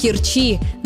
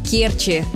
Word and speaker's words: Керчи: Нередко можно услышать вариант «в Керчи», но это ошибка. Правильно – в Керчи, с ударением на первый слог.